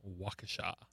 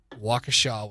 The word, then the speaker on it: Waukesha: How would you say the town that is spelled Wausha, Wisconsin. – Waukesha. – That is correct. – Yes. – Waukesha, Wisconsin.